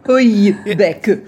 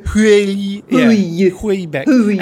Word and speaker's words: Houellebecq: Houellebecq. – Houellebecq.